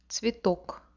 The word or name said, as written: tcvetok